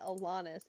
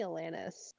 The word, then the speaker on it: Alanis: Why are you pronouncing it Alanis? It's definitely Alanis.